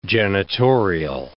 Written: janitorial

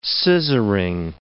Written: scissoring

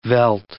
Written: veld

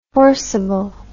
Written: forceable

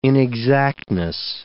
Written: inexactness

